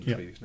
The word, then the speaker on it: trie: Yeah, we call them trees now. – I think we pronounce them tree, but it is a T-R-I-E. – T-R-I-E, and in fact, he even mentioned closure in his Fortress presentation.